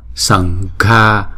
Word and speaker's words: Sangha: Namas sankhaya, sang sần chứ không phải là sầm ha. Sangha, ga, chữ g hờ đọc là g g.